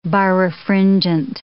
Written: birefringent

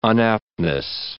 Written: unaptness

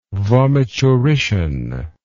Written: vomiturition

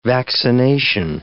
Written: vaccination

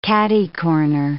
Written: catty-corner

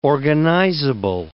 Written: organizable

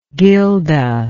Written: Gilda

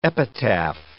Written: epitaph

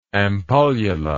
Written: ampullula

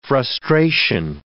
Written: frustration

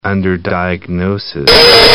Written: underdiagnosis

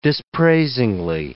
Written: dispraisingly

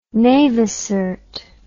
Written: navicert

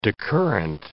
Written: decurrent

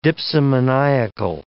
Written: dipsomaniacal

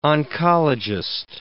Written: oncologist

